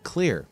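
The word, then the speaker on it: Xlear: Well, we'll have a chat with Nate Jones, founder and CEO of Clear. That's Clear spelled with an X.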